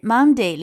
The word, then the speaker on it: Monday: 好，那么发音上要注意的是 Monday 里面的 o 发的是短音啊，Monday。